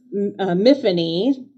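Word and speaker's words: Myfanwy: It's Miffany, spelled with a W because it's Welsh.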